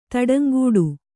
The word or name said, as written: ತಡಂಗೂಡು